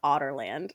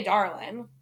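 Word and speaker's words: Adarlan: My least favorite, though, is the kingdom and how it's actually pronounced Otterland. I hate it. – Yeah. Otterland. – That's how it's, like, canonically said. – Yeah, in the audiobook. – I say Adarlin.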